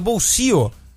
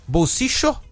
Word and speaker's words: bolsillo: O caçapo em espanhol, que se pronuncia bolsio. Que tem as 3 pronúncias do duplo L lá na Argentina. – Isso. – Né? Bolsicho, como se fosse um CH, né?